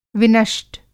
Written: विनष्ट

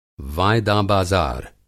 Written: वायदा-बाज़ार

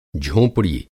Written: झोंपड़ी

